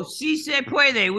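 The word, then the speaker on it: sí se puede: Is se pueda? – Oh, si se puede, which means yes, we can.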